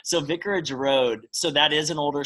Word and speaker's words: Vicarage Road: Yeah, so with Vicarage Road, am I pronouncing that right, or are there too many? – That is correct, yeah. Vicarage Road, yes.